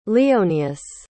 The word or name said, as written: leonius